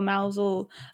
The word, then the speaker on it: Mousehole: Well, it's spelt Mousehole, but I believe it's pronounced Mosul. – I think it's like that Mosul or Mousel, because I remember reading a a book, a children's book about a cat who lived in in Mousel.